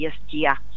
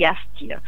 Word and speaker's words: ястия: Напоследък, тъй като сме засипани от всевъзможни кулинарни предавания, много често слушаме как се готвят и ястия. Това не е правилно. Готвят се и ястия. Искам да направя едно уточнение, една корекция по повод на ударението, защото е много дразнещо, когато не е книжовно в официалните медии.